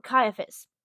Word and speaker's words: Caiaphas: The Jews led Jesus from Caiaphas. Sorry, I keep pronouncing that name wrong. Caiaphas.